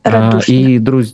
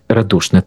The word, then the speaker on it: Радушне: Журналістка Першого Криворізького, розповідала нам про трагедію у селищі, воно називається Радушне. Дякую. – Радушне. – Радушне, так, дякую за те, що ви виправили наголос, так, бо я у вас не живу, але разом з тим мені завжди приємно, коли колеги підтримують в цьому плані.